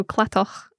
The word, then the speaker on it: Cladach: There's a place on the island of Arran, just near to Brodig, called, well, in English, Cladach. – Mm-hmm. – Is that the same thing? – Yes. So Clatoch is the Gaelic way to pronounce that.